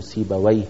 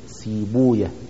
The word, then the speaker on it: سيبويه: فلهذا كان يعني كان المحدثون يحبون أنها ألا تنطق ويه بفتح الواو وإسكان الياء على نفس، لكن يضمون الواو، يضمون الواو، يعني مثلا يقولون سيبويا، يضمون أقصد ما قبل الواو بحيث الواو تصبح مد، يعني سيبويه يقول مثلا سيبويا، راهويه يضمون ما قبل الواو، يقولون راهويا، إسحاق بن راهويا.